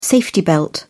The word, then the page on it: safety belt: British English